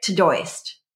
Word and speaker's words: Todoist: it looks like Todoist, T-O-D-O-I-S-T. And it's pronounced Todoist, list, Todoist.